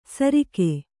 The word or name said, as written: ಸರಿಕೆ